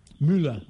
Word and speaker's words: Müller: Müller.